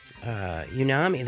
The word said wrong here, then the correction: Umami: the House of Unami. – House of Umami. – House of, how you say it? Umami? – Umami. – Umami. – Yeah, there you go.